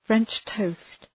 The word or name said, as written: French toast